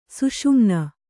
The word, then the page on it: ಸೂಸುಮಣ್ಣು: sūsu maṇṇu